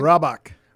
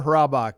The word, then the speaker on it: Hrabak: Hrabak. James R. Hrabak.